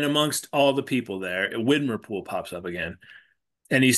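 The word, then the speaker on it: Widmerpool: Windermere Pool and Widmer Pool.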